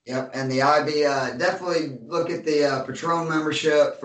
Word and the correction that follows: Patreon: for our Patreon. I always say that wrong. Patreon, or you can see RC going, learn how to talk, you damn country redneck.